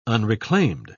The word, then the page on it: unreclaimed: British English